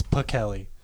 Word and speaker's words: Pichelli: Yeah, I've been saying it wrong. It's Pichelli.